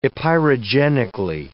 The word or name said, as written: epeirogenically